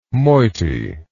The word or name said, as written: moity